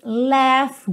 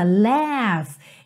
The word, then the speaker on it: laugh: Outra palavra para você não se deixar intimidar por ela, laugh. Essa palavra rir, ok? L-A-U-G-H, e a pronúncia dela é laugh. Laugh, como se fosse L-E-F mesmo, tá? Laugh. Não precisa nem th-th-th. É um som de F mesmo.